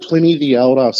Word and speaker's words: Pliny the Elder: It's called Pliny the Elder. So Pliny and then the Elder.